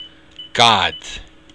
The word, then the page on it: gad: Irish